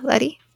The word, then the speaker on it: Lettie: Letty, for short. – Letty, okay. – At least that's how I'm choosing to pronounce her nickname. L-E-T-T-I-E. – Yeah, Letty. Sounds right.